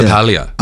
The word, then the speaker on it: LaPaglia: LaPaglia.